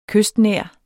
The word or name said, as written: kystnær